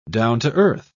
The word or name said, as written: down-to-earth